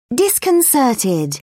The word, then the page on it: disconcerted: British English